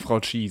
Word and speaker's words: Kyi: Ich glaube tatsächlich, der letzte Teil wird Chi ausgesprochen. Man könnte so wie AKK, man könnte ASSK machen.